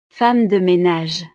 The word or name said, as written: femme de menage